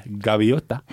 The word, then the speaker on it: gaviota: Skrives som, mm. skrives V, men uttales med, som en liten blaute B. Gaviota.